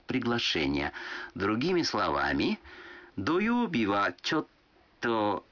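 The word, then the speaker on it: чотто (ちょっと): Чотто означает дословно «немного», но растягивая окончание и ослабляя ударение, так что получается «чотто».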